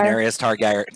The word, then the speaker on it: Daenerys Targaryen: Daenerys Targaryen.